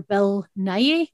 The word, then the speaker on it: Nighy: And I thought one of the guitarists actually looked a lot like, you know, the actor Bill Nighy? Is that how you pronounce it? – Yes.